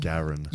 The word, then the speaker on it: Garen: Garen or Garrett or something? – Garen.